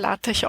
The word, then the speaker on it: LaTeX: Also LaTeX geschrieben, aber LaTeX ausgesprochen.